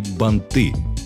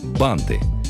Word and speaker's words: банты: Чтобы первоклашки не ошибались в ударении и не говорили «банты», объяснять им правила занятия неблагодарное. Да и большинство взрослых его не запомнят. Слишком много исключений. Проще запомнить конкретное слово. Запоминаем. Банты. Во множественном числе ударение не меняется, как и в уменьшительной форме. Бант, банты, бантики.